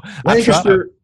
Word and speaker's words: Lancaster: Big old city, Lancaster, if you want to say it right. – I know. – Lancaster, right outside of Danville.